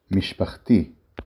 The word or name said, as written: משפחתי